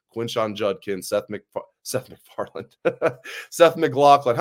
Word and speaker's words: McLaughlin: He was like, it's McLaughlin, like Sarah McLaughlin.